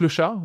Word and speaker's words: Le Chat: And then we took Le Chat, which is which we named in a French way, but if you pronounce it in English, you get various results.